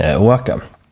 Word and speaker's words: Oaxaca: Det är oroligheter i södra Mexiko, i delstaten Oaxaca. Jag har faktiskt kollat upp på internet hur man uttalar det namnet. Det uttalas Oaxaca.